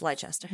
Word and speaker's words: Leicester: Do you mean Leicester as it's spelled? – Yes, Leicester. – Way too many letters for how it's said out loud? – Too many.